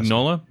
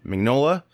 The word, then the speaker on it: Mignola: So to get into more movie topics, Hellboy: Rise of the Blood Queen, creator Mike, how do you say his last name? Mignola. – Mignola.